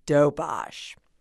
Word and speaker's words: Doberge: Citing the city's love for everything French, Ledner named her version Doberge, D O B E R G E a new word New Orleanians have been pronouncing in fancy, pretentious ways ever since. But us old timers, including Charlotte McGehee, still say dobosh.